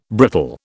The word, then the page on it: brittle: British English